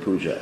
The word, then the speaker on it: puja: Bhakti doesn't mean, it's not the same thing as puja. Because don't pronounce the word puja as puj. Puj means stool.